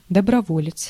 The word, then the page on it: volunteer: [ˌvɒl.ən.ˈtɪɚ]; /vɑl.ənˈtɪɹ/; /vɒl.ənˈtɪə/; /ˌvɒl.ən.ˈtɪəɹ/